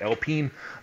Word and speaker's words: Alpine: They're going to go with Alpine which uh, to many Americans is Alpine, but it's pronounced Alpine.